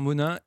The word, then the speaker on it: Monein: À propos de Monin, pour ceux qui n'habitent pas dans la région, il faut savoir que c'est écrit M-O-N-E-I-N, et ça se prononce Monin.